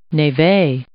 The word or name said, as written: névé